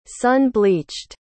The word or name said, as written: sun-bleached